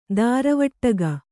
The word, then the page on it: ದಾರವಟ್ಟಗ: dāravaṭṭaga